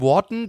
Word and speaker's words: Wharton: Adam Wharton, glaube ich, ja. spricht man so ja, aus.